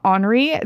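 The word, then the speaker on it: On're: It's like Onre. It's spelled O-N-R-E. O-N apostrophe R-E.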